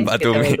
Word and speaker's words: Batumi: No lo voy a pronunciar bien, así que lo digo. El, el jugador este que viene del Dinamo Batumbo, como se llame, Batumi.